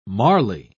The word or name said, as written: marly